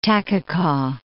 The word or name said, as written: Takkakaw